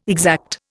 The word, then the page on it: exact: Indian